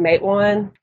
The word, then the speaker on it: Matewan: We've always pronounced it Matewan because a lot of the places around here are named from Indian places.